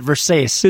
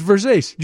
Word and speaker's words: Versace: F-A-T-O-N-E. Isn't that how you say it? – Yeah, he buys his clothes at Versace. – It's Versace.